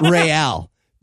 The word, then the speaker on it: Real: I love that they pronounce it Real, Isn't that, that's just Spanish for royal.